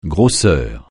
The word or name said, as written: grosseur